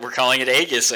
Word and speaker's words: Aegis: And if you push learn to pronounce this thing, they call it, and what Destiny pronounced it was Aegis.